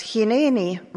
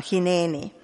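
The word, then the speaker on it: Hineeni (הנני): Seda hääldatakse natukene erinevalt ja kirjutatakse ka natukene erinevalt meie tähestikuga. Ehk siis kas Hineeni või Hineeni, et seal oleks nagu E ja I kombinatsioon keskel, või siis kahe pika E-ga hääldatuna Hineeni. Ja kui ma eile otsisin YouTubeist, et kas sellele sõnale mingeid vasteid on, siis tegelikult tuli sealt välja päris mitu jutlust teemaga Hineeni.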